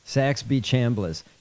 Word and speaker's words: Saxby Chambliss: There are, I just saw a, yeah, Saxy Chambliss. – That's the guy. I couldn't say his name correctly because it's insane. Saxby Chambliss? – Saxby Chambliss.